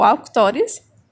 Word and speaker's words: Avctoris: E outra opção de registro é o Auctores, que se escreve A-V-C-T-O-R-I-S,